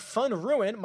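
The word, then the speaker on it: Van Rooyen: But you've got DV3, Davis Love III, HV3, Harold Varner III, are 1 and 3, and then Eric Van Ruin, my buddy Mark Immelman told me how to pronounce that properly, is second on this list.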